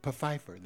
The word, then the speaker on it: Pfeiffer: So they go into the funeral home, and they're greeted by a guy named Mr. Pfeiffer. – Pfeiffer? – No, Pfeiffer. The P is pronounced. – The P is not silent.